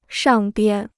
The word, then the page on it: 上边: shàng bian